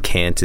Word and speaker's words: can: So here, you know, can is spelled as like K-A-E-N, and can't is K-A-E-N-T. – Mm-hmm. – Right. Okay. Anyway, so when spoken, however, can often sounds like Ken, sounds like the name Ken.